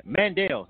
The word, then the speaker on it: Mandel: Mandelman, all right. Mandel. My bad. Mandel. I'm sorry, I was saying it wrong. Mandel. The artist's name, Mandel. The song is I-T-I-L-Y, which stands for I Think I Love You.